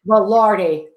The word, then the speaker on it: Velarde: Is Director of Marketing for Black Mesa Winery in, and I hope I pronounce this correctly, Uvalde, New Mexico. Is that correct? – Velarde.